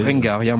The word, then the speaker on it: Berengaria: En 1925, sur le Berengaria, je sais pas comment vous prononcez. – Berengaria, moi je dirais.